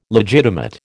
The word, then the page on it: legitimate: American English